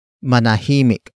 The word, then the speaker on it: manahimik: and that is manahimik. Manahimik. Manahimik. Manahimik is spelled as M-A-N-A-H-I-M-I-K.